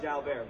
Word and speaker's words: Jalbert: Technically, his last name is pronounced Jalbert, but we're not splitting hairs. He's French.